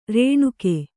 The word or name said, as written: ಋಣಿಕೆ